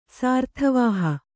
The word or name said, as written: ಸಾರ್ಥವಾಹ